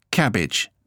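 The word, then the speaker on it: CABG: Coronary artery bypass graft, pronounced cabbage.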